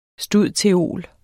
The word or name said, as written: stud.theol.